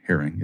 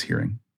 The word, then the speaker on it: Heering: Then you're going to take your cherry Heering. And by the way, Jules, great job on saying it correctly. It's not herring, it's hearing.